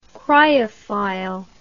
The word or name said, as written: cryophile